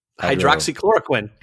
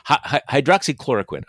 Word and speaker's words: hydroxychloroquine: Hydroxychloroquine. – Hydroxychloroquine.